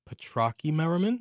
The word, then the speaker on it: Petrocchi-Merriman: It's Lisa Petrocchi-Merriman.